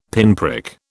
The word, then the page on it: pinprick: British English